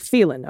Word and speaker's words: Phelan: Not quite a town twister, but I grew up in the high desert of California, not far from a town called Phelan, pronounced like Phelan. Okay, Phelan.